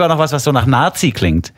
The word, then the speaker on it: Nati: Nazi. – Mhm. – Ja. N-A-T-I, nicht Z, sondern T. – Ah, okay. – Ja. Man schreibt Nationalmannschaft ja auch nicht mit Z.